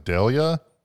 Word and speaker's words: D'Elia: Chris, I think his last name is pronounced like D'Elia.